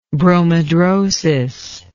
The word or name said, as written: bromhidrosis